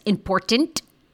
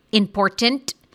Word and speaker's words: important: The most annoying part of this interview is the way Olivia Jade says important, important, the entire time. It's important for me to be here. Like, important. No, nobody actually pronounces both T's. Important. Like, the middle one's silent.